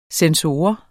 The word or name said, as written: sensor